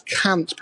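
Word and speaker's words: Kant: And that's Cant, pronounced in the Maltese way, basically a private part slang word.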